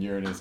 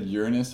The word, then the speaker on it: Uranus: And I know I've said Uranus a bunch of times and y'all are like, why are you saying it like Uranus? That's how astrologers pronounce it, so that we don't have to say Uranus, and y'all fucking giggle, but Uranus is funny.